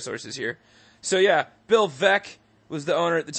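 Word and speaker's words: Veeck: Turns out the owner of the White Sox last name is pronounced Vec, not Veek.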